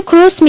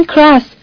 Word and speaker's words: کروس: حتی بلد نیستن نام خانوادگی ما رو درست تلفظ کنن و به جای کروس میگن کراس.